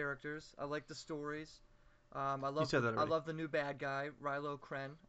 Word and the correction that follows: Kylo Ren: Kylo Ren. – Kylo Ren.